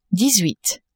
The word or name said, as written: dix-huit